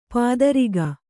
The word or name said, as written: ಪೈತೃಕ